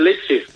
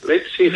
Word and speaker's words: Leipzig: Pues es como cuando dices Leipzig, que no es Leipzig, Rafa, es Leipzig. Leipzig.